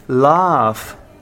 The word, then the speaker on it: laugh: Oh, you don't know how to pronounce it. It's not love. It's laugh. – Laugh. – Yeah. Laugh. Laugh, be careful, yeah? Laugh, when we laugh, we do ha ha ha ha. That's laugh, not laugh.